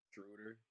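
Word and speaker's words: Schröder: How do you say Schroeder? – Schroeder.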